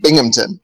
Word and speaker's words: Binghamton: It's Binghamton.